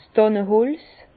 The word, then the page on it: 's Dànneholz: Bas-Rhin; Herrlisheim